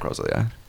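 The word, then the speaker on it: Rosalía: Am I saying her name? – Rosalia. – Right? Rosalia.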